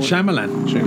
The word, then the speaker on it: Shyamalan: Am I pronouncing that wrong? – M. Night Shyamalan. That's how we've always pronounced it.